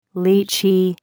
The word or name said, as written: litchi